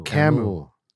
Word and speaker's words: Camus: Camus.